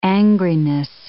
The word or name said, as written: angriness